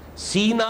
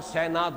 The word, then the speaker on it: سینا: سینا اور سینا دونوں اس کے تلفظ ہیں،